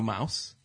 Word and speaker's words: Maus: You know Mouse. – Yeah. Yeah. Oh, no, yeah. Mouse, yeah. Not spelled like mouse, if you're listening. – M-A-U-S.